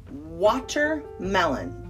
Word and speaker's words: watermelon: And in the United States, we call it watermelon. Like there's a D in it, but it's really a T, watermelon.